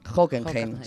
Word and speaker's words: Hockenheim: Y quizá el punto más importante para cualquier friki del automovilismo es su paso por el circuito de Hockenheim, no sé si lo digo bien. – Sí. – Sí, Hockenheim, sí, sí.